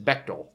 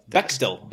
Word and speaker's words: Bechdel: Yeah, and I'm not trying to go anti Bechdel with it, but did I pronounce that right? – Anti Bechdel? – The Bechdel test? – Bechdel. – Bechdel. – Bechdel test. Yes.